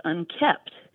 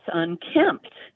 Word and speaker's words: unkempt: And my husband said, that's not how you say it. It's unkept. I said, no, it's unkempt.